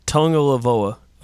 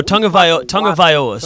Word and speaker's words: Tagovailoa: I think Saban dilly-dallying around with Hurts or Tagovailoa, and am I saying his name right? Because for as many times as I've said we had to look it up, and I had to look up the pronunciation. Tagovailoa. – I think that... – Oh, Tagovailoa. Sorry. – Yeah, that's it.